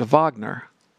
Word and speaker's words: Wagner: It comes from an opera, Lohengrin, by a guy named Wagner. It's spelled like Wagner. Richard Wagner was German.